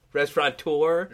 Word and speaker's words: restaurateur: Restaurateur. As though you're giving a tour of a restaurant. – Yeah, right? Restaurateur. – Whoever invented that word. D-U-R-R-E, I believe.